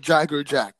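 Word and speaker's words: Jaegerjaquez: No, Grimmjow Jagger Jack or something. – But that's not how it's spelt. It's spelt Jaeger. But like, I guess you say Jagger. Jagger Jock.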